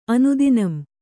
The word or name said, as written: ಅನುದಿನಂ